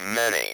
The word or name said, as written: many